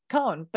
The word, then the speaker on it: Coan: my last name is cone but it's spelled c-o-a-n there's no h in it